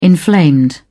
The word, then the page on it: inflamed: British English